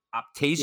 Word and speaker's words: Optasia: And am I pronouncing this right, PJ? Is it Optasia? – Yes, correct. Well done.